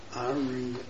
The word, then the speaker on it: Ariane: Spell it A-R-I-A-N. – N-E. – In other words, it's N at the end. But it's pronounced Arian. – Yes. – Not Arian. Arian.